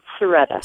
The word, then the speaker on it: Cerreta: Is it Coretta or Serretta? – It's Serretta.